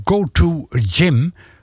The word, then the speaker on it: Go to Gym: Dit was trouwens een nummer van Go to Gym, zo spreek je het uit.